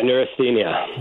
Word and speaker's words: neurasthenia: A word that you use a lot in your book, and correct me if I pronounce it incorrectly, neurasthenia. – Yeah, neurasthenia.